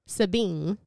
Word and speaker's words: Sabine: In Sabine, which is spelled S-A-B-I-N-E, Sabine.